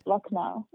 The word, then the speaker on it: Lucknow: Lucknow.